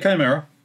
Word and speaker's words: Chimera: And also, Alpha Primaris is sorry for the pronunciation, Chimera? – Mm-hmm. Yeah, Chimera, with it.